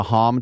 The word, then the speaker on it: Hamm: Well, I came in second and then got remember the twins, the Hom twins, pronounced properly, or H-A-M-M, Hom?